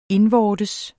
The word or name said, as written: indvortes